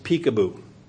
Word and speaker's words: Picabo: Her first name is spelled P-I-C-A-B-O, but it is pronounced Peekaboo.